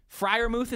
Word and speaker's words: Freiermuth: The kind of the consensus second tight end, I believe Freiermuth is how he pronounces his last name.